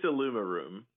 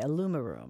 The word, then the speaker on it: IllumiRoom: Hey, I think it's Illumiroom. – Illumiroom, okay. – Yeah. You're pretty close either way, really. – Yeah, I guess there's kind of just, it's more about the cadence of how you say it.